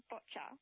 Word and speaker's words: boccia: And have I pronounced the sport correctly, bocce? – It's actually called boccia. – Boccia, that's right.